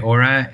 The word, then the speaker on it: Aura: Yeah, you're saying it right, Aura.